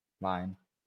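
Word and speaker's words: Lyon: Is that how you say it? Leon? – Lion.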